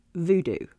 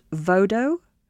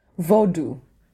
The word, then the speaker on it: Vodou: So the first thing that I have learned, because you are here to speak to me about Haitian voodoo, is that it's not pronounced voodoo. Is that right? – That's right. That's exactly correct. – It's pronounced vodou? – Vodou.